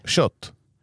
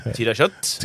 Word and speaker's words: Kött: Kött. Ja. K-Ö-T-T. – Tyra Kött.